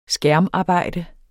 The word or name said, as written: skærmarbejde